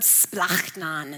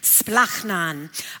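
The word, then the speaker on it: splachnon (σπλάγχνον): So in your homework, we took a look at the word splachnon, and that's how you say it. It's splachnon.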